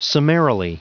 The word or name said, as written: summarily